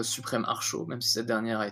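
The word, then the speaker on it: Archot: Et pour le coup, non, la prononciation, c'est bien Archaud, il me semble. En tout cas, ça s'écrit A-R-C-H-O et un T, il me semble, à la fin.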